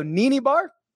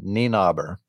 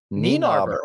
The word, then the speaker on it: Nienaber: Garrick Higgo, Wilco Nienaber. You can check my pronunciation on that. And Eric Van Rooyen. – Let's try this again. Nienaber. – Nienaber. Oh, there we go.